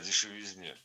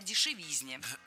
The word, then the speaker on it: дешевизне: Кстати, о дешевизне.